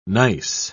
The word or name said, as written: nice